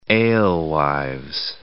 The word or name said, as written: alewives